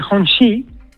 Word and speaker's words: Hongqi: Sí, se escribe Hongqi, pero se dice Hongxi, ¿no?